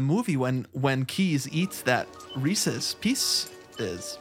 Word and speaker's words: Reese's Pieces: Now, how do you pronounce this product? – Okay, I say Reese's Pieces. Hold on. – Me too.